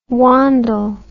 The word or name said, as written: Wandle